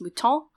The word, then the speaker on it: mouton: But in French, the word is then pronounced mouton, and it means both the cooked sheep, but it can also mean the live sheep.